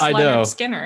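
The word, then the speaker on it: Lynyrd Skynyrd: No, it's they told you it's pronounced Leonard Skinner.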